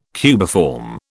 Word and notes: cubiform: British English

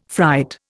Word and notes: fright: Indian

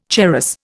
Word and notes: charas: Indian

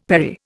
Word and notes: perry: Indian

